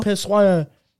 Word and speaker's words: pissoir: No, it might be pissoir. Pissoir. – Pissoir. Pissoir. That's how you pronounce it.